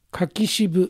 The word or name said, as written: Kakishibu